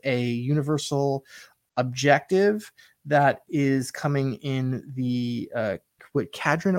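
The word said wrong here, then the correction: Kharadron: Overlords. Is that how you say it? – Yeah, Caradron Overlords. Caradron Overlords.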